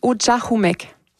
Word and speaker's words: Ujahumek: On le prononce Ujahumek.